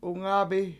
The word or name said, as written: 7ungAbi